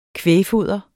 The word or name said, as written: kvægfoder